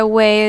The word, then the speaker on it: OUAI: And just to clarify, way is spelled O-U-A-I.